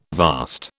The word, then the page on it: vast: British English